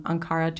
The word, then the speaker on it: Ankara: Amanda is from Southampton and Balor is from Ankara, Turkey.